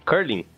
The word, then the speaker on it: curling: Curly? Curling. Curling. Curling, assiste, essa. É muito legal, curling, ele não sabe nem pronunciar o nome.